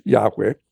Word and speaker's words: Yahweh: They did not get instructions from Moses on Sinai that they had to believe in one God, Yahweh, which is probably the pronunciation of the Tetragrammaton.